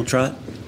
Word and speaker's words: Trott: My name is Daniel Trott. Last name is spelled T-R-O-T-T.